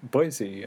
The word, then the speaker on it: Boise: Well, Mike, I want to thank you for joining me today from Boise, and I don't know if I'm saying that right. How do you say it again? – I think you nailed it. – Oh, I did? Boise. Okay.